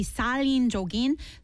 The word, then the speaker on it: 살인적인: And actually, I mispronounced that. So it's, instead of 설린적이다, it should be 살인적인.